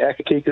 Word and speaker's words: Accokeek: Accokeek, Maryland. Am I saying that right? – Yeah, yes, right. Accokeek is correct.